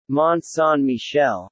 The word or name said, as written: Mont Saint Michel